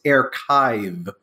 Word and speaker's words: Airchive: And thank you for pronouncing it correctly because Jason always was like air chive because that's how it's spelled. like, you mean like the onion? You mean like like something that I'm like, no, it's archive.